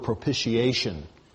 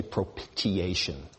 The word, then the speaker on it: propitiation: When you look at the word propitiation, you pronounce it with a soft T. You don't say propitiation.